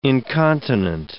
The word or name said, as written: incontinent